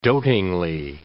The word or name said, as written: dotingly